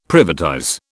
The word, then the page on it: privatize: British English